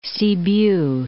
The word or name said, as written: Sibiu